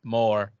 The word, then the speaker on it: mort: Which I think is delightful because the French word for death is more, which is spelled the same way.